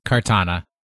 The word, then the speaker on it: Kartana: And pair it with Tapu Fini and Katana. Is that how you say it? Kartana? Katana? – Kartana. Like the sword.